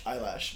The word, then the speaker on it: Eilish: Eyelash.